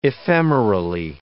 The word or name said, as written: ephemerally